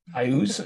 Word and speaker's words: Iuz: Ooze. I think it's pronounced Ooze, I-U-Z from Greyhawk. Is it pronounced Ooze? – I-Ooze. – I-Ooze? – Yeah.